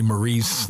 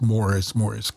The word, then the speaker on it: Maurice: I always want to say Maurice. That's the way the name is spelled, but it's pronounced Morris Gibb.